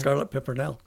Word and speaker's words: Scarlet Pimpernel: Scarlet Pimpernel, yeah.